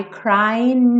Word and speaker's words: crying: Crying termina com G. Normalmente esse G não é pronunciado. São pouquíssimos lugares do mundo, pouquíssimos sotaques que falam crying, crying. Tá? Então, normalmente a gente pensa nesse G sem som.